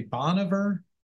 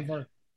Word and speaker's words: Bon Iver: And I always say it wrong. Maybe Dan, you can help me. Boniver. Is that right? – I think you're right there. – Okay. Boniver. Yeah.